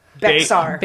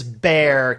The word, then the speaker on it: Bexar: So how would you guys pronounce Bexar County? – Bexar. – You'd be wrong because it is Bear County.